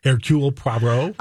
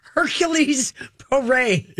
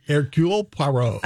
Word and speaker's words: Hercule Poirot: One is Hercule Poirot, who is in Madame I've never said his name right. Say it again. – Hercule Poirot. – I've always called him Hercules Poirot. – Close. – Say it again. – Hercule Poirot. – Oh, I know how to spell it. – I'm glad you said that, not me. – But I don't know how to. I don't know how to pronounce it because it looks like Hercules.